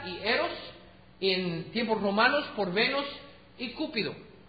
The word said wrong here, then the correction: Cupido: o Cupido, ¿cómo se dice? Cupido.